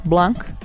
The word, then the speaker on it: Blanc: O inquisitivo e charmoso detetive Bennett Blanc é recrutado...